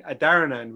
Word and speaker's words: Adharanand: So I grew up being Adharanand with the H silent.